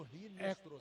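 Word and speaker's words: Hinestrosa: A pronúncia com R, né? Se escreve com H. Mas a pronúncia em espanhol é com R. Marino Rinestrosa.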